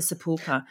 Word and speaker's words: Sepulchre: Is that how you say it? The Sepulchre. – Yes, or Sepulchre. Maybe the Holy Sepulchre, yes.